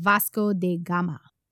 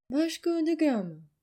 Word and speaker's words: Vasco da Gama: Should I attempt to say his name in a Portuguese accent? – Oh, go ahead. Vasco da Gama.